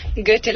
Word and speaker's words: Goethe: No sé si he pronunciado bien, habría que empezar por ahí, ¿no? Por ese Goethe. – Goethe, lo has pronunciado muy bien.